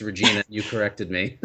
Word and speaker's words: Regina: Yeah, no, I told you, Regina, it rhymes with.